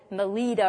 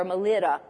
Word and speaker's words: Melita: Now, the Bible calls it Melita or Melita. I've heard it pronounced both ways.